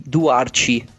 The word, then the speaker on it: Duarte: Leo Duarte, anzi Duarci per pronunciarlo correttamente.